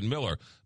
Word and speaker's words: Mueller: M-U-E-L-L-E-R. Looks like Mueller, but you pronounce it Miller.